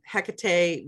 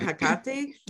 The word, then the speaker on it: Hecate: I'll float between Hecate, because I know that's how most people know her nowadays, and Hecate, yes. – which is my understanding is closer to the Greek pronunciation of her name. – Yep. – It just somehow naturally more natural to me, to call her Hecate.